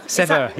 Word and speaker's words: Seva: Seva? – Seva. Yes, Seva. Yeah. – I knew I would say that wrong. Tell me about this philosophy. Seva. – Seva. So Seva is a word for selfless service in Hindi.